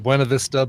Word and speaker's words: Buena Vista: past like Buena Vista, which is spelled Buena Vista but pronounced Buena Vista, and Fairplay and and uh, Breckenridge and all these.